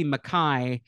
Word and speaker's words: Mackay: Mackay, I've said Mackay a couple times on the podcast, and then I realized as I was preparing for this episode that it should be Mackay, because that is how the name is pronounced in Scotland.